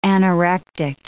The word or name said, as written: anorectic